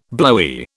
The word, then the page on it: blowy: British English